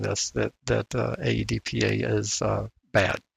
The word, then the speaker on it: AEDPA: commonly referred to as AEDPA. Is that how it would be pronounced? AEDPA? – That's fine.